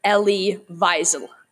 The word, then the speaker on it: Elie Wiesel: Hi, I just wanted to note that in this podcast, we will be saying Eli as the author of Night, his memoir. His correct name is Elie Wiesel.